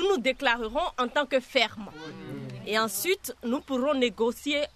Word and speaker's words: FERM: Vous avez des idées F-E-R-M. – On le prononce ferme.